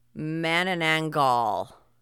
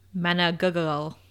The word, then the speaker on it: Manananggal: we're going to call it a Manananggal. M-A-N-A-N-A-N-G-G-A-L. – Yeah. – Manananggal.